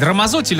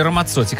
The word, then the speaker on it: Рамаццотти: И действительно спрашивают, как вот, ну, правильно произносить, Рамаззотти или Рамаццотти? Как пицца? Все-таки как пицца.